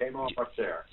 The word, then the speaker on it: Hamo of Auxerre: Not even sure if I'm saying it right. Hamo of Auxerre, who... – Yes, right, that's correct, Hamo of Auxerre.